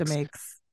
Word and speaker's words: Demakes: But the original, those three original members, Roger Lima, Vinnie Fiorello, and Chris, let's say Chris DeMakes. That's the pun on his name he uses in the podcast title. So I'm assuming that's how you say it. – Yeah, I would stick with that.